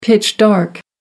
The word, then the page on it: pitch dark: American English